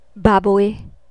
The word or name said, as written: baboy